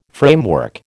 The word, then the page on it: framework: American English